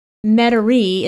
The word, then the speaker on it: Metairie: And then Metairie is pronounced like Metairie in Louisiana, because I had a hard time pronouncing that.